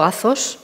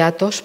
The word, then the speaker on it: Athos: Bueno, lo pronuncias muy bien, lo pronuncias eh, en griego, Azos. Yo, yo lo digo Monte Athos, porque aquí es como lo decimos, o sea, como queráis.